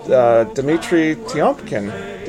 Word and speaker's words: Dmitri Tiomkin: And that opening music was by the incomparable Dmitri Tiomkin. Did I say that right? – You did.